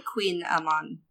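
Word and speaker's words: Kouign-amann: It's pronounced like Queen Amon. – Queen Amon. – Kouign, Queen Amon. Yeah.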